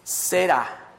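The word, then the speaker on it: zera: Aquí hay una palabra, la palabra descendencia en el, el, en el hebreo se pronuncia Sera, Z-E-R-A, e o semilla.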